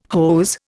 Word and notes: hose: Indian

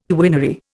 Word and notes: quinary: Indian

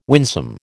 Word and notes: winsome: American English

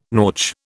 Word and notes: nautch: British English

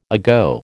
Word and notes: ago: American English

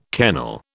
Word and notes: kennel: British English

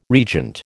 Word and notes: regent: American English

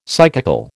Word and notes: psychical: American English

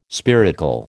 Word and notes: spiracle: American English

